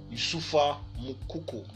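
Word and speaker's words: Youssoufa Moukoko: Youssoufa Mukoko.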